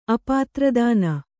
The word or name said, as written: ಅಪಾತ್ರದಾನ